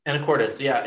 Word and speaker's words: Anacortes: This guy was looking into Washington and wanted to know where, and I might butcher the name of this town. Anacortes? – Anacortes? – Anacortes, yeah.